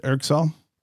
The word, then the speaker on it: ergSol: Did I say that right? ergSol? – Yep. Mm-hmm.